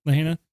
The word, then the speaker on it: Lahaina: All homes in Lahaina, is that how you say it? – Lahaina.